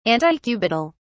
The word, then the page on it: antecubital: British English